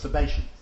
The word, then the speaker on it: Sabbatians: Now you'll hear the expression Sabbatians. No, it's Sabbatians. That's how it's pronounced.